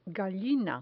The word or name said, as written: gallina